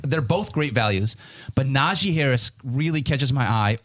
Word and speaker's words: Najee: He gets very irritated if you say Najee, which is for, you hear a lot. – Najee. – It's an E. Yeah, say it how it's spelled.